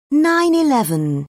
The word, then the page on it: 9-11: British English